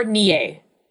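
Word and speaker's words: née: So this can either be ni or nie. Both are correct.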